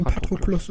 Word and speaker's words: Patroklos: Oder ähm, Patroklos? – Ähm, nee, Patroklos ist schon richtig. Ja ähm, zumindest wird so im Deutschen ausgesprochen.